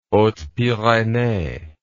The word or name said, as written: hautes pyrenees